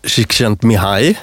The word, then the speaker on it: Csikszentmihalyi: Och då så beskriver Csikszentmihalyi.